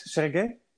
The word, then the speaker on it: Sergei: Am I saying your name correct, Sergey? – Yes, absolutely.